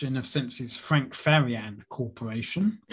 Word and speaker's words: Farian: So the group was actually created by German record producer Frank Farian, or Farian, F-A-R-I-A-N.